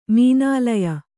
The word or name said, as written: ಮೀನಾಲಯ